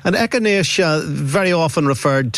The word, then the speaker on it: echinacea: Echinacea.